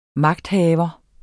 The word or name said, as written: magthaver